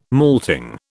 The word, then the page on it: malting: British English